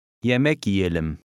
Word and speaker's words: yiyelim: And number 4, the middle Y is almost silent. – Yemek yiyelim.